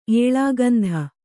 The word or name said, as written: ಏಳಾಗಂಧ